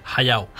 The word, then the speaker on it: Hayao: Hablando de pronunciación, ¿Hayao o Hayao? – Hayao. – Hayao. Hayao. Sí. El tema de la H. Vaya. – Aspirada.